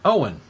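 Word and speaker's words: Eoin: By the way, Ian Colfer's first name is pronounced Owen. I did the Ian on purpose because it's actually Owen Colfer.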